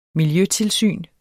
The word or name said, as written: miljøtilsyn